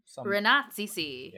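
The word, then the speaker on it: Rannazzisi: It's Ranazzisi. It's a hard name to pronounce. – Ranazzisi. – Yeah. – It's the S-I at the end. If it was just Ranazzi, it would be really easy.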